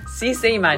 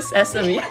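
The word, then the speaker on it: sesame: 깨. 시세임 아니고 세서미.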